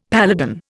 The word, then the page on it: paladin: Indian